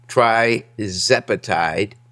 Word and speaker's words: tirzepatide: It's tirzepatide, Mounjaro, and of course semaglutide is Ozempic and Wegovy.